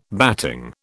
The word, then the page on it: batting: British English